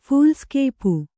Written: ಫೂಲ್ಸ್‍ಕೇಪು

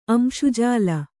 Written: ಅಂಶುಜಾಲ

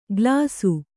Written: ಗಲಾಸು

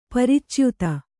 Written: ಪರಿಚ್ಯುತ

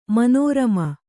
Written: ಮನೋರಮ

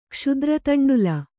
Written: ಕ್ಷುದ್ರತಂಡುಲ

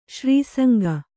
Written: ಸಿರಿಸಂಘ

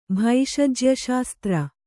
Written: ಭೈಷಜ್ಯಶಾಸ್ತ್ರ

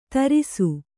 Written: ಧಾರಿಸು

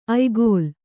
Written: ಆಯಗಾಲು

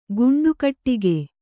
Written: ಗುಂಡುಕಟ್ಟಿಗೆ

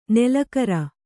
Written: ನಾಲಿಕೇರ

